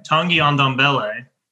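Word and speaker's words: Tanguy Ndombele: It was Cessignon, Tongi on the let me say it right. Tongi on Ondombele. That's how you say it.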